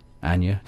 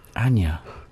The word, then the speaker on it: Anja: Anya. – Do you not pronounce the J? – Don't think so. – Anya.